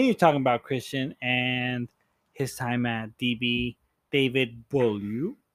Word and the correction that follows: Boulud: Danielle. Daniel Boulud. Okay, Daniel Boulud. – I could never say that right.